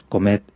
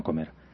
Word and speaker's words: comed: Y aunque esa D es verdad que se pronuncia muy relajada y se acerca mucho en la pronunciación a una R, es comed y no comer.